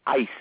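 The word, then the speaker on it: ice (εἰς): In this passage here in Colossians, there's a little preposition for, and it's the Greek epsilon sigma, and it's pronounced like ice, as in ice cube.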